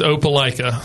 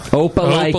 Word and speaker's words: Opelika: It's Opelika. – Opelika. – Opelika, yeah. Okay. Everybody wants to say Opelika, Opelika. No, it's Opelika.